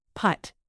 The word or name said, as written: putt